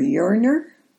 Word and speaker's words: Yearner: This is Catholic Vitamin Y for Yearner.